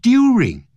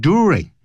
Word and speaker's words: during: He came of age during, 它不会念成 during, 它念 during。